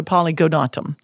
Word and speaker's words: polygonatum: So, the first one is the genus, and there's a lot of polygonums, but this is a polygonatum. There's an extra little syllable in there, and its species name, it could be like multiple species, you know.